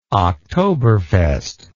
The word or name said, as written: octoberfest